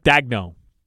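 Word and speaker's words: Daigneault: Mark Dagno.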